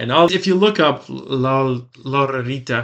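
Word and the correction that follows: Lotería: how do you say it? – Loteria. – Loteria.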